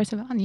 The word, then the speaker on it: Sivan: Is that how you pronounce his name? Sivan? – Troy Sivan, yes, nailed it.